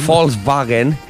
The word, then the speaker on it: Volkswagen: No, pronuncia bien Volkswagen. – Volkswagen.